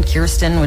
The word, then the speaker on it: Kirsten: I'm like, I'll answer Kristen, Kirsten, Kirsten, which is how you say my name, but I don't blame people.